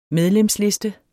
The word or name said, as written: medlemsliste